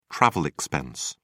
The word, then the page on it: travel expense: British English